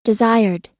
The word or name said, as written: desired